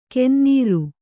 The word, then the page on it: ಕೆನ್ನೀರು: kennīru